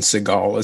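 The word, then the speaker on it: Segel: TNT, where it's like not Jason Siegel. It's like uh, I think it's Jason Segal is how you pronounce his name.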